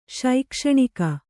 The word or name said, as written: ಶೈಕ್ಷಣಿಕ